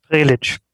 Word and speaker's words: Prelicz: Prelicz.